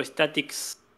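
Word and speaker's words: Static-X: Bueno, es una canción que se escucha de la banda Static X, o Statics, como se pronuncia,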